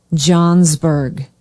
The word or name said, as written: johnsburg